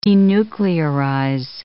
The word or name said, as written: denuclearize